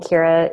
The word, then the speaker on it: Kira: And Barr is spelled B-A-R-R, double R. – That's right. That's right. And Kira is like Kira Knightley.